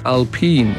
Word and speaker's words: Alpine: They pronounce it as Alpine. Yeah, that's because it is a French subsidiary.